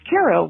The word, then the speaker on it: Cairo: But it is pronounced Cairo, like the syrup.